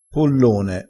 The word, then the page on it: pollone: dialettale